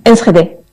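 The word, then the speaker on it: Enschede: ¿Cómo se pronuncia bien, por cierto? – Esgede. – Esgede.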